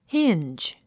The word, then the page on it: hinge: híndʒ; ヒィンジ